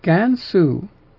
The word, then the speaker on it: Gansu: But the pronunciation of Gansu. Gansu is this province. Is the way that this name is pronounced.